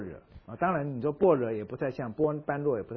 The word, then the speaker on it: 般若: 当然，在佛经里面，除了这个 Bodhi 这个字以外，另外一个字常用一个字，你可以念成般若，般若哈。其实据我所知，佛教正式的念法，如果我没有念错，是念成般若，破音字哈。我们中文普通念是般若嘛，可他们佛教徒不念般若的，般若。